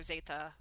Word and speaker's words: zeta: Yeah, so I've got a female Barbarian and I named her Zeta, which is how you say Z in Spanish.